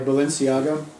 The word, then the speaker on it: Balenciaga: Oh, and I noticed there was an advertisement for, like, Balenciaga.